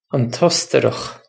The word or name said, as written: an t Ostarach